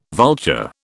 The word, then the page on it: vulture: British English